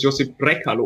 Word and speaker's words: Josip Brekalo: Ja, also ähm, ich glaube tatsächlich, dass er übrigens Josip Brekalo oder so ausgesprochen Ach, okay. wird, also eher kurz.